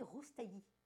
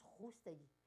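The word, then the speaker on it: Roustaee: Et c'est réalisé par Saed Roustaï, je crois qu'on dit Roustaï, on prononce Roustaï.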